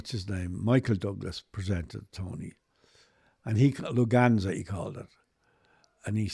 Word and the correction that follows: Lughnasa: I said, it's lunacy. You don't pronounce the G.